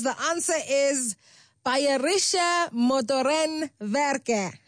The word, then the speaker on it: Bayerische Motorenwerke: Bayerische Motorenwerke. – Ah, Bayerische Motorenwerke. – Werke. Werke. Bayerische Motorenwerke.